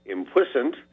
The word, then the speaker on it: impuissant: I am P-U-I-S-S-A-N-T. I am P-U-I-S-S-A-N-T. And according to the wordsmith, you know, us regular folks pronounce this impuissant.